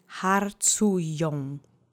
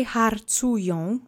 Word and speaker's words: harcują: Let's say harcują together three times. The accent is on the middle syllable.